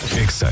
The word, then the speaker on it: Exa: Exa FM 89.9. Este programa contó con espacios comerciales y o patrocinados. No se dicen éxitos. Se dice Exa.